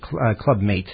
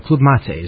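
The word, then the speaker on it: Club-Mate: I say Club Mate, and I know that's wrong. because that's how it's spelled, but Club Mate is how we probably should say it from now on.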